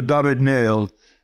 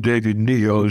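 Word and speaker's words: David-Néel: I loved Alexandra David-Niel, if that's pronouncing her name right. – Yes, that's right. The pronouncing for Alexandra David-Niel. She was one of the most unlikely, but also extremely well-informed traveler explorer. She was actually in French, so she's not called David Neal, as some people think, but David Neal.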